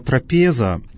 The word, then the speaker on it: трапеза: Ну, а слово «трапеза», кстати, обращаем внимание на то, что необходимо с правильным ударением читать это слово «трапеза».